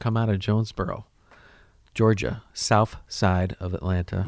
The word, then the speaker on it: South Side: We call it the South Side like 2 Chainz likes to pronounce it and everybody else who's from there in the hip-hop culture. Mm-hmm. South. S-O-U-F. Side.